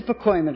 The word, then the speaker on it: Pacoima: I went to Pacoima to live.